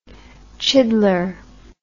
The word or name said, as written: chiddler